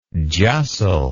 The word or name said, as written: justle